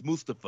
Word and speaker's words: Mustafa: That's how the man pronounces his name, sir. It's Mustafa.